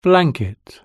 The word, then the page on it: blanket: British English